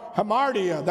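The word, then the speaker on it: Hamartia (ἁμαρτία): He goes through to say, if you've missed it by just a little bit, and yet we understand the Greek, the word is hermardia or something like that. Hamartia, that's it.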